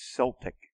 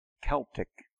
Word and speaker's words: Celtic: C-E-L-T-I-C. It's more of the French way of saying it to have it with an S, as Celtic. But the more historic way of the Latin Greek way of pronouncing it is Celtic with a K. K. Celtic.